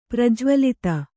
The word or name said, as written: ಪ್ರಜ್ವಲಿತ